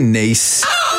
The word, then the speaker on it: Nice: Yeah, a Nice biscuit pronounced Nice like the name of the French city.